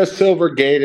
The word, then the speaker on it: Silvergate: Is it, did I say it right? Silvergate? – Yeah, Silvergate. It's SI.